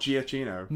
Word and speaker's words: Giacchino: It's Giacchino. Michael Giacchino. – Not Giacchino. – No, it's Giacchino. That's how you say his name. We are now corrected for all times.